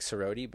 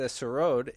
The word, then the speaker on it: sarod: You can spell it S-A-R-O-D or yeah. S-A-R-O-D-E. It looks like sarodi, but it's sarod.